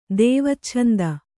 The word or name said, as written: ದೇವಚ್ಛಂದ